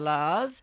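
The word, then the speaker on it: La's: There She Goes by the Lalas, rhyming with cars, just in case you didn't know, Hazel.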